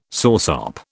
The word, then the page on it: soursop: British English